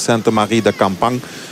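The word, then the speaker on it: Sainte-Marie-de-Campan: Zo graag in het plaatselijk dialect Saint-Marie-de-Campan. Zoals ze dat hier zelf uitspreken.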